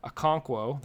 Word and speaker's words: Okonkwo: Patrick, I'm going to mispronounce this again. I know I spelled it phonetically. Okonkwo, there we go, as another forward.